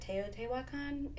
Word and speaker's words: Teotihuacan: I don't know if I'm pronouncing this correctly, but Teotihuacan, it's a little Mexican. – I think you nailed it.